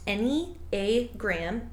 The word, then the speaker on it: Enneagram: The Enneagram, pronounced Enneagram, is a geometric figure that maps out the 9 fundamental personality types of human nature and their complex interrelationships.